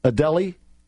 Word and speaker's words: Adelie: Adelie is how you pronounce it.